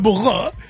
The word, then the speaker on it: bureau: Exactamente, se pronuncia burro.